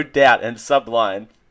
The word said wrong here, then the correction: Sublime: Also, I like how you put the wrong emphasis on the wrong syllable with Sublime.